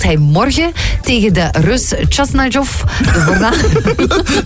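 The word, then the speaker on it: Khachanov: Chasnajov. – Volgens mij was dat vlekkeloos uitgesproken.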